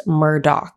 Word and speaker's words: Murdaugh: It is spelt Murdaugh, but it is correctly pronounced Murdaugh.